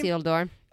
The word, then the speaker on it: Isildur: Isildur.